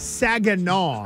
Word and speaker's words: Saginaw: Saginaw.